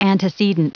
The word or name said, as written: antecedent